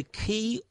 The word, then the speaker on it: Qi: including Bluetooth connectivity and the key wireless charging. It's spelled QI. Is it QI wireless charging? So the top panel of the digital alarm clock from Laser is key enabled. So that's QI enabled.